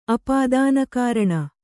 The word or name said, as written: ಅಪಾದಾನಕಾರಣ